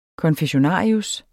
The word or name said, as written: konfessionarius